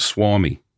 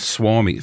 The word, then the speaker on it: Suomi: It's actually called Suomi. – Called what? – Suomi. Finland is spelled S-U-O-M-I.